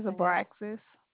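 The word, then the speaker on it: Abraxis: I'll write my name is Abraxas.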